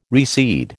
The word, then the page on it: recede: American English